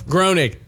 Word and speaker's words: Groening: It says Matt Groening. Is that how you say it? Who created The Simpsons? – Groening. Yeah.